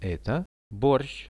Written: eto borsh

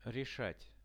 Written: reshat

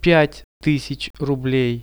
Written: 5000 rublei

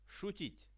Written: shutit

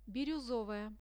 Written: biruzovaya

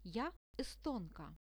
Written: ja estonka

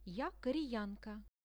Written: ja koreyanka

